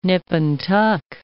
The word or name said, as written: nip and tuck